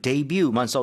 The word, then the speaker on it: debut: Well, the way Americans say this word is Right. – debut. – debut. – Debut. But maybe the Korean is closer to the French.